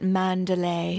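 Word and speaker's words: Manderley: It's funny because it's like, it's spelled Manderly. – Oh, right. – But I think they say it, like in the movie, they said it Mandalay.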